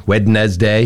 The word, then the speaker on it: Wednesday: R-E-C-O-M-M-E-N-D. – Yeah. Recommend. It's recommend. – People have trouble with the word Wednesday. Wednesday. – Yeah. Wednesday. – Yep. I agree with that one. – It's because the D is silent.